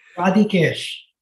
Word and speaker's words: Radhikesh: Radhikesh. – Radhikesh. – Radhikesh. – Okay, I wanted to quick it up a lot. I wanted to collapse the syllables.